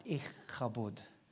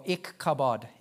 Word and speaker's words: Ichabod: Ichabod is how you pronounce his name, or Ichabod if you're an Aussie.